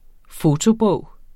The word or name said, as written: fotobog